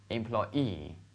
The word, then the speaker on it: employee: 你看，前面我们都是特别忍受啊，忍辱负重的，轻轻的读 employee 哈、啊，最后才 e 出来，是不是啊？就很奇怪这个读音，OK。所以的话，大家注意一点哈、啊，这个词再跟我读一下嘛，employee。